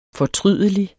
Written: fortrydelig

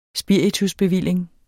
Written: spiritusbevilling

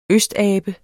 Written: østabe

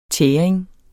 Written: tæring

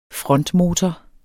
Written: frontmotor

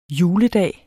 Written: juledag

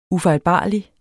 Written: UFEJLBARLIGE

